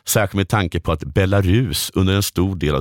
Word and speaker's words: Belarus: Den svenska uttalet har långt u-ljud och betoning på sista stavelsen. Belarus.